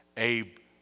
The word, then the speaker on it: Abraham: And in the Hebrew, the difference in those two words are just one little letter that looks like an apostrophe. And you pronounce it with just a breath. Instead of Abram, it was Abraham.